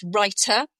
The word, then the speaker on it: Rytr: And then another one is Writer, budget friendly and suitable for small scale projects or beginner. And that's spelled R-Y-T-R. – Yeah, check the spelling. Often these are little play on words. So yeah, Writer.